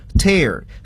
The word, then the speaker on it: tear: 但是那个发音呢要读作 tear，所以呢我们应该把它叫做 homographs，同形异音异义词。